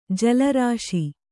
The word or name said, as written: ಜಲರಾಶಿ